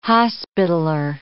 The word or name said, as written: Hospitaller